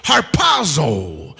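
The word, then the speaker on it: harpazo (ἁρπάζω): The Greek word translated rapture is pronounced harpazo.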